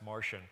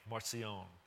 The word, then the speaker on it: Marcion: You know who actually pronounced Martian. That sounds like he came down in a spaceship for us. So most people like make it French, Marcion.